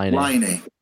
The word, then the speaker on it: Laine: How do you pronounce his name? – Laine.